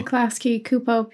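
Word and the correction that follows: Klasky Csupo: people. Did I say it right? – Klasky Chupo. – Klasky Chupo. See, I told you.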